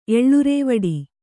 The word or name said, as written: ಎಲರ್ವಟ್ಟೆ